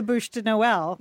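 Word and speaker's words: Bûche de Noël: I'll just go buy the Bouche de Noël. – Yeah. – Am I pronouncing that correctly? – You got that. Spot on. Spot on.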